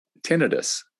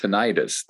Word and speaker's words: tinnitus: tinnitus, or sometimes pronounced tinnitus, that persistent ringing in the ears that nobody knows how to get rid of.